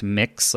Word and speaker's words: Mx: It's MX and it's pronounced mix, like you're mixing a bowl to make pancakes or something.